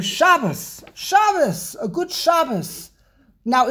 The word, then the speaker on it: Shabbos: In Yiddish, it's the way you say the word is Shabbos, because every single word in Yiddish, the emphasis is on the first syllable.